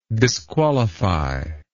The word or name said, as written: disqualifiable